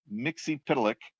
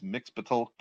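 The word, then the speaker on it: Mxyzptlk: Their take on Mr. Mixie Pitalik, that's how I pronounce it, but I know it's sometimes it's Mixpitalk, but more time than not, it's Mixie Pitalik.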